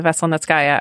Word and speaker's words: Veselnitskaya: Veselnitskaya.